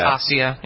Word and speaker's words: Asia: It looks like Asia, but it's Asia.